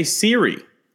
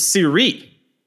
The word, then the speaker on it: Siri: I actually found out it's not Jose Siri. It's Siri. It's got an accent on the second I.